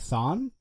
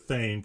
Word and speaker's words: Thane: So then is it Thon then? If it's them, I'm just kidding. We'll move on. – Well, they pronounce it Thane because it's T-H-A-N-E, so it's a long A.